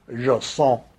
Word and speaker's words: JSON: There's a lot of argument about how you pronounce that. I strictly don't care. I think probably the correct pronunciation is JSON.